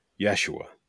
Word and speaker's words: Yeshua: So his name is changed from Hoshea to, we say Joshua, but it's Yeshua.